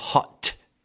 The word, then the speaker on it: hot: To be full of hot air. Ricordando le cose di pronuncia qua importante. H. Quando c'è l'H lo devi dire. Hot.